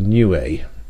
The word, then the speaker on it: Niue: The 6G's the expedition to Niue, that's how it's pronounced apparently, Niue, that's N-I-U-E, island in the Pacific, should be underway by now.